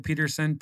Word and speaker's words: Pedersen: But just Michael Pedersen, P E D E R S E N on LinkedIn, and I'd love to connect with anybody on LinkedIn.